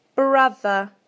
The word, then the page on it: brother: British English